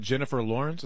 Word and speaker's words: Jennifer Lawrence: Is it Jennifer Lawrence? Is that her name? – I think that's how you pronounce it, Tina, right? – Yeah.